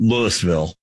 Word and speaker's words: Louisville: It's Louisville. Not Louisville, you know.